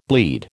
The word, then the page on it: bleed: American English